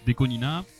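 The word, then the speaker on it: DECO*27: Et en fait, ça se prononce Deconina, puisque 2, ça se prononce Ni, et 7, ça se prononce Nana. Donc ça fait Ni Nana, donc Nina.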